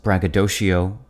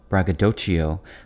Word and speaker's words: braggadocio: This word has a few alternative pronunciations braggadocio, braggadocio, braggadocio, and braggadocio.